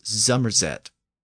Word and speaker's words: Somerset: Now, this feature still exists in the southwest of England, where you'll hear the region of Somerset pronounced as Zummerzet.